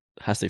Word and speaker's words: hace: Hace frío. Muy caliente. – Hace frío. – Hace frío. – The H is silent.